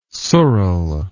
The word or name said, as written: sural